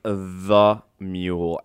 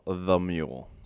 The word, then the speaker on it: The Mule: And my name is The Mule. And on that, emphasis on the and then mule. The Mule.